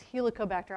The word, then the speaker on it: Helicobacter: This is a very common pronunciation problem. It's Helicobacter.